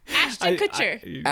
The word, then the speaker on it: Ashton Kutcher: Ashton Kutcher.